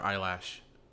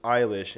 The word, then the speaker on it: Eilish: Is it Billie Eilish or Eyelash? – It's Eilish, and it makes you sound very 40 years old when you say Eyelash.